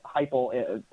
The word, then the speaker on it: Heupel: What do you think about that, as well as the hire of Josh Heupel, sorry if I'm mispronouncing his name. – No, you got it right. – At Tennessee. I mean, that's, Yeah. I mean, that to me, the Josh Heupel hire seems kind of like last ditch, but at the same time, who are they going to hire? – Patrick, over there.